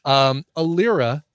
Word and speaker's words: Alyrah: Alira is the performer. It's spelled A-L-Y-R-A-H.